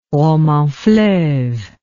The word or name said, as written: roman fleuve